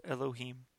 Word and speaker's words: אלוהים: The second word is pronounced Elohim.